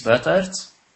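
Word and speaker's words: Buytaert: My last name, Boytaert, is slightly more difficult, and I don't have any mind tricks or any tricks for that.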